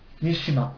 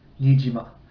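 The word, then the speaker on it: Niijima: so his his preferred pronunciation of his name was uh, Nishima, but when you see his name written in English pu- uh, publications, it's it's more commonly written Nijima with Nijima.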